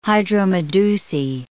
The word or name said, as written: hydromedusae